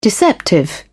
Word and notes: deceptive: British English

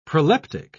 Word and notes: proleptic: British English

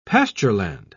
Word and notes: pastureland: British English

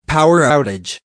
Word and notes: power outage: American English

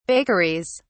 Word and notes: bakeries: British English

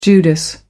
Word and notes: judas: American English

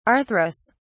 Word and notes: arthrous: British English

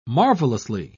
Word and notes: marvellously: British English

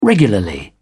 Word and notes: regularly: British English

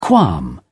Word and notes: qualm: British English

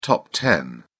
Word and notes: top ten: British English

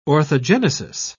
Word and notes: orthogenesis: British English

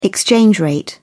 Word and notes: exchange rate: British English

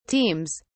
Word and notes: teams: British English

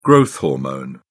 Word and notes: growth hormone: British English

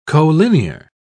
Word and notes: colinear: British English

